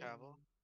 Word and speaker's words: Cavill: Cavill. – Cavill.